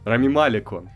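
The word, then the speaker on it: Малек: Да. Неправильное ударение. – Рами Малик он. – Он ра... Э, нет, он не Рами, он Рами.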